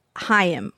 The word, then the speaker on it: Haim: I think your names sound like you're in a kind of alt rock, like Haim type group.